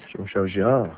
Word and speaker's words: Jean-Michel Jarre: Is it Jean-Michel Jarre, is that right? – Jean-Michel Jarre. – Jean-Michel Jarre, sorry. The E is silent.